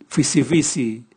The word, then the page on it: vwisivwisi: Raga